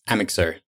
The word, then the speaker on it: Amixr: Okay, so how do you pronounce it? – It's Amixr. – Amixr.